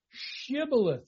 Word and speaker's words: Shibboleth: Pronounce Shibboleth. You have to pronounce the Shin sound.